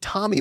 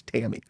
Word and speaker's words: Tammy: And they were pronouncing it Tommy Baldwin, like it's Tammy.